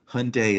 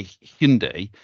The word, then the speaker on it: Hyundai: They've done a big push on it in the UK, where you say Hyundai. In the UK, everyone called it Hyundai. So they've just done a big push on this is how you say it.